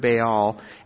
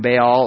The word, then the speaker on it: Baal: The wickedness increases, not just worshiping him, but now building a temple as well for the worship of Baal. Just a little background on Baal, or Baal, depending on how you pronounce it.